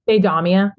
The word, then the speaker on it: Deidamia: Deidamia. I'll type the pronunciation in the chat. – Deidamia.